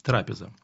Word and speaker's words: трапеза: Если мы с вами обратимся к словарю, то с греческого слова «трапеза», а именно ударение делается на «а», «трапеза», означает «стол».